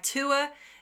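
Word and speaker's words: Tuatha: It's an Irish word, and it's tua is the first word and day. So that's spelled T-U-A or T-U-A, T-H, and then D-E with an accent called a fada on the E.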